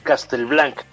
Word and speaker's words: Castelblanc: me dicen que yo pronuncio la H y no debería de hacerlo, que es Castelblanc, tal como tú lo dijiste.